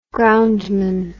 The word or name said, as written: groundman